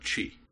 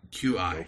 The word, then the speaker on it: Qi: So the next thing on my list here is I'm going to talk about Qi. – Qi. – Q-I. Oh. It's apparently pronounced Qi, which took me a while because I was pronouncing it Q.